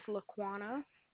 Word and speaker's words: Laquana: My name is Laquana.